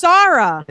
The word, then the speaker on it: Sahra: It's Sarah. – It's Sarah. – In England, it's Sarah. Here, it's Sarah. S-A-H-R-A.